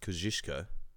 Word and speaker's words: Kosciuszko: I know that it's pronounced Kosciuszko.